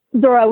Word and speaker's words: Zora: Zora with a Z, Z-O-R-A.